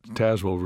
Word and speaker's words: Tazewell: Well, let's begin with your favorite place on the planet, Tazewell, Virginia. Take us there, if you would. – Well, first of all, I've got to commend you on pronouncing it right, because I rarely hear people pronounce it correctly.